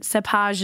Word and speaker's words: cépage: And cépage is the, you know, bougie French word for blend. So whenever, if you ever encounter that, if someone says, hey, what's the cépage on that wine? It's spelled C-E-P-A-G-E.